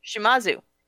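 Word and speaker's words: Shimizu: And directed by Takashi, how do I say this? – Shimazu.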